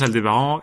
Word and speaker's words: Aldébaran: que ça se prononce Aldébaran et du coup peut-être faire un choix de leur dire Aldébaran.